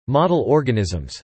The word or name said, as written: Model organisms